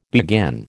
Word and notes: beguine: American English

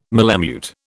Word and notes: malemute: British English

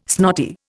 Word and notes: snotty: Indian

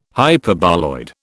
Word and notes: Hyperboloid: British English

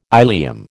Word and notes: ilium: American English

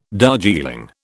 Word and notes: Darjeeling: British English

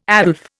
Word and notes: Aslef: Indian

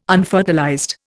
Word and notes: unfertilized: Indian

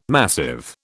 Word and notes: massive: British English

